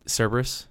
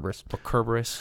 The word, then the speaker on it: Cerberus: Cerberus. Or Kerberos, depending on your pronunciation.